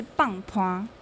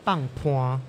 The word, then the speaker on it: 放伴: 那另外比较特别的地方是说，因为我们的发音是来自于台语，像放办就是棒坡。 – 棒坡，对，没错，棒坡。